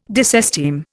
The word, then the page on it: disesteem: Indian